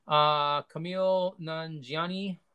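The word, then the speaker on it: Kumail Nanjiani: Camille Nanjiani, I hope I pronounced that right, as Kingo.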